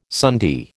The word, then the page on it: sundae: American English